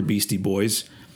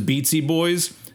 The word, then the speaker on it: Beastie Boys: If you haven't figured it out yet, the Beatsy Boys or the Beastie Boys. I always really liked that about them. That once. Once there was that track with Biz Markie mispronouncing their name as Beatsy Boys,